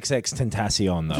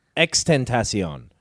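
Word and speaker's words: XXXTentacion: It's not XXX Tentacion, though, is it? – It is, isn't it? – It's X-Tentacion. – But there's three X's. – There's three X's, but you don't pronounce all three, so you've learned fucking nothing from this.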